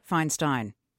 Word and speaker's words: Feinstein: Feinstein.